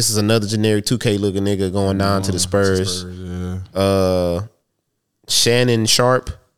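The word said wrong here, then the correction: Shaedon Sharpe: I don't know if I'm saying that right. – Shaden Sharp. – Shaden Sharp, yeah.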